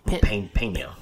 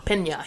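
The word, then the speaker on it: Peña: Michael Pena. – Pena.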